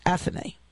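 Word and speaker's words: Athénée: So I'm at the Plaza Athenae, A-T-H-E-N-E-E, with an accent over it. So it's pronounced Athenae.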